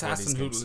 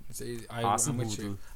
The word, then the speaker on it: Hasenhüttl: They're picking up since Hasenhüttl's come in. An average of 1.5 points per game. – Actually, you speak of average of 1.5 points per game. – Now that I think since Hasenhüttl has come in, we've mentioned him 100% of the podcast.